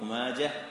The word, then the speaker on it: ماجه: يا يا صاحي انطق ماجه بالهاء بلال جاجه، بالهاء بدون همزه، يا صاحي انطق ماجه بالهاء بلال جاجه.